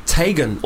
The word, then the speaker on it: Teigen: Chrissy Teigen is actually meant to be pronounced Tegan or Tigan.